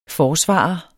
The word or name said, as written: FORSVARERE